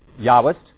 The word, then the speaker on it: Yahwist: The J stands for Yahwist, and it's those parts of the Hebrew Bible use the name of God as Yahweh, because this came from Germany, right? So they pronounce a J like Yah. So Yahwist. The Elohist is a strain that uses the term Elohim for God.